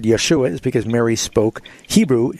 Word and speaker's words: Yeshua: If you were going to say Jesus' name in Hebrew, it would be Yeshua.